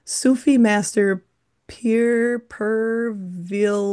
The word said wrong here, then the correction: Pir Vilayat: Here. – Here, help me with this one. – Pir Vilayat Got it. Thank you so much.